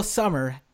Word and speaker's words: Sommer: Will Sommer, thank you for joining us. – Hey, thanks for having me. – Did I say your last name right? – Yeah, just like the season.